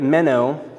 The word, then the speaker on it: meno: The word abide is the word meno, pronounced men-o.